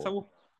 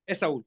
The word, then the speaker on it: Saul: Saul, yeah. – But you with a better emphasis on the S.